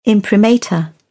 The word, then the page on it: imprimatur: British English